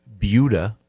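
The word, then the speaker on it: Buda: And we were learning how to pronounce Beulah.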